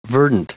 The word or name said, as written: verdant